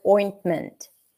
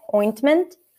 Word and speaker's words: ointment: Anur is asking, I just got a question about the word ointment. Ointment. The T is silent and sometimes the T is pronounced clearly. Both are correct. Okay. Sometimes when people try to be clear, they'll pop it. Ointment. Ointment. A lot of times it's just going to be ointment.